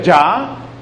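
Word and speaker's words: ja: El nombre tan extraño significa oui, que es sí en francés, y J-A, que se pronuncia ya, que es sí en alemán.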